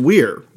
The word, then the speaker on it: Weir: I think this is how you say his name, right? – Yeah, because you just yelled at me yesterday for saying liar, so. – Yeah, I'm pretty sure it's Weir.